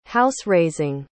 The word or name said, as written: house-raising